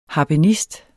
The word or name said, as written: harpenist